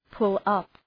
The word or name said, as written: pull up